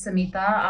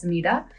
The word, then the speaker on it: Samita: As Greg said, my name is Samita Ali Khan. A lot of people, particularly in the Western world, know me as Samita, but I do go by both pronunciations.